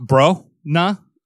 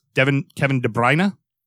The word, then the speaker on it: De Bruyne: It's not bro-na, it's like where we think it's an O, it's an eh. So it's Kevin De Bruyne. – Okay. – Is how it's supposed to be pronounced.